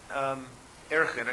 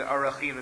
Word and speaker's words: Arachim: is what we call Erchin, I guess, or more appropriately, Arachim would be the proper grammatical pronunciation of it.